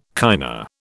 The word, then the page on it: kinda: British English